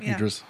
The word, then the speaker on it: Idris: Is that how you say it? Idris? – Yeah, Idris.